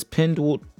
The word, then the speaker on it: Pinduoduo: It says Penduoduo. It's spelled P-I-N-D-U-O-D-U-O is the company.